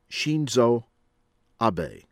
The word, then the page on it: Shinzo Abe: from Japan